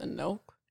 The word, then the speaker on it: Enoch: So anyways, egregores specifically come from the Book of Enoch, or Enoch. – I think it's Enoch. – Enoch. Okay. That would make sense.